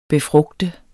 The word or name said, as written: BEFRUGTER